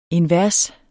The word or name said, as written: invers